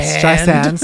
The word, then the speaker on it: Streisand: Streisand, soft S, as the top selling female artist with the most certified albums.